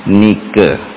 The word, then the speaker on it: Nike: Tulisannya Nike. Nike. Bacanya Nike.